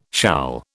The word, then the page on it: shall: British English